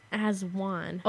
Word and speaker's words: Aswan: And I'm going to talk about Aswan. I think that's how you say it. – Aswan? – Aswan. – Oh, Aswan.